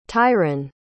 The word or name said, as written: tyran